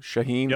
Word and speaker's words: Shaheen: those talented wide receivers, two tight ends, you got uh, Shaheen. I hope I pronounced that correctly. Shaheen, yep.